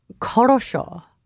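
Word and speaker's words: korosho (хорошо): And that's from a Russian word, korosho, which means good.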